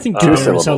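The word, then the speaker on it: d'Hubert: Keith Carradine plays Armand Dubert, maybe? – I think Dubert is how they pronounce it. – Dubert. Yeah. Dubert. The T is dropped, yes.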